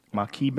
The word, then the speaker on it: maqui: Maqui berry, M-A-Q-U-I. How would you say that? Maqui berry? – Maqui.